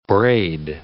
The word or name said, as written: braid